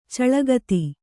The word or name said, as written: ಚಿಳ್ಳೆಕೇತ